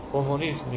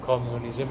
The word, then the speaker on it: کمونیسم: سوسیالیسم می‌گیم، سوشالیزم نمیگیم، کمونیسم میگیم، کامیونیزم نمیگیم.